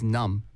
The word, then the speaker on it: numb: What it means is numb. – 맞아요. – N-U-M-B. – 맞아요. 네. N-U-M-B. Numb. – 네. – 하지만 우리 그 B 발음은 네. 안 나와요. – 그쵸. – 네. 그냥 numb.